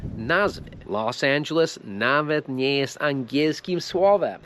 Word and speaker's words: Los Angeles: Los Angeles.